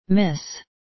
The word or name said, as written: miss